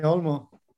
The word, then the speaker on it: Olmo: Is it Olmo? – Yeah, Dani Olmo.